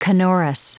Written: canorous

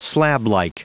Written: slablike

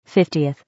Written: 50th

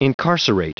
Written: incarcerate

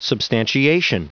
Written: substantiation

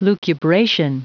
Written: lucubration

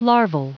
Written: larval

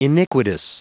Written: iniquitous

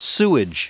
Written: sewage

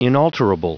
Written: inalterable

